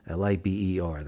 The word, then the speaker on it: Liber: Liber. That's the tricky one, but that's how you say it. It's kind of a Latin thing.